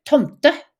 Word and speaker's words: Tomte: And in Swedish, they call the exact same creature Tomte. – Okay. – T-O-M-T-E.